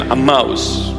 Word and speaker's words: Emmaus: Emmaus. Emmaus. That's the proper pronunciation, Emmaus.